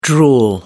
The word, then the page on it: drool: American English